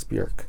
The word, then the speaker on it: Björk: I got to really start saying her name right now, because it's not Bjork, it's Björk. Like Jerk.